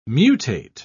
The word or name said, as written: mutate